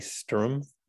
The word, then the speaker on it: Stroom: We've got Ziggy Devrient, who's the founder of a record label called, pronounced correctly, Strum, S-T-R-O-O-M, from Belgium.